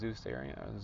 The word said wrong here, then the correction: Zoroastrian: Zeus, Zoroastrian. Zoroastrians.